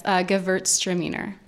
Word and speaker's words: Gewürztraminer: Gewürztraminer. – Great. And Gewürz means spice. – Right. – So that's kind of that natural characteristic of the grape. Yeah. – Yeah. – But this Gewürztraminer is a very classic Thanksgiving pairing, right? – It certainly can be.